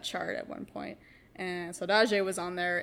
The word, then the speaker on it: saudade: Saudade. – Saudade.